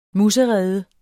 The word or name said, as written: muserede